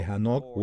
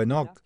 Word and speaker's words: Hanok: Se remonta al libro de Génesis, donde hemos hablado de Hanok o Enoch, según cómo pronuncies su nombre. En hebreo es Hanok.